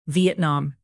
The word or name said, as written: vietnam